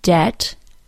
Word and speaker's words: debt: Climb, doubt, debt, bomb. And here are the 2 words with a silent G. Campaign, design.